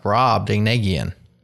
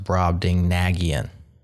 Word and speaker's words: Brobdingnagian: It's pronounced Brob Dingnagian. – So take that, Matthew. – Take that, Matthew. – Yeah, check out your own YouTube videos for pronunciation and step back, rocking my world again. – Brob Dingnagian. – If it ends in IAN, it's Armenian. So, fun fact. – A slight alteration, it could also be pronounced Brob Dingnagian. So it could be Nagian or Nagian. I've heard them both now. But Brob Ding is correct.